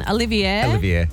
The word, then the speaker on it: Olivier: Olivier, yep.